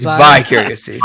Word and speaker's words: vicariously: No, it's vi. It's vicarious. – It's vicariously.